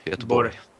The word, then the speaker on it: Göteborg: Well, we would say Göteborg. – Yeah. So there is sort of the G is sort of its own little syllable there at the end, soft little. – Göteborg. – Maybe it's just coming off of it. I don't know. Some people really make that a last syllable, and some people sort of like tail off and don't.